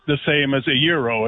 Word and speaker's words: gyro: Gyro is not the proper pronunciation. – That's fine. – Euro, like why, like the year.